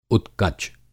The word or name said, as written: उत्कच